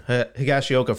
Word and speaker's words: Higashioka: Higashioka for like, you know, 2-3 mil on a year or two contract, right?